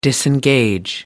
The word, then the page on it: disengage: American English